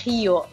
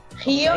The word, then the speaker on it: Rio: Beh, allora, le 5 cose turistiche di Rio, che poi si dice Rio in portoghese. – Ups, e quindi Rio de Janeiro sarebbe pronunciato in maniera corretta? – Rio Ok. – de Janeiro. – Non so se ci riuscirò a pronunciarlo correttamente. – Beh, comunque all'italiana va benissimo Rio.